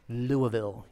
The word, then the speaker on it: Louisville: Louisville, you gotta say it right.